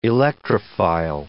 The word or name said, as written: electrophile